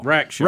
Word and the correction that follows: Rackstraw: Rackstraw. – Yeah. Mm-hmm. – All right. – Rack and Straw.